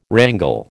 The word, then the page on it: wrangle: American English